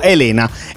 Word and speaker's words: Elena: Con l'accento Elena.